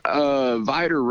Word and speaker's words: Vidor: Vidor.